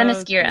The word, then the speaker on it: Themyscira: Themyscira. – Themyscira.